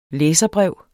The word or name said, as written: læserbrev